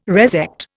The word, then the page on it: resect: Indian